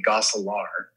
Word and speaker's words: Gosselaar: But it, it, it the, the cor- correct pronunciation is probably Gosselaar because that's the closest to how we say it in Dutch, which is Gosselaar. Gosselaar.